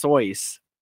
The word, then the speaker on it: Seuss: His name is actually pronounced Soyce.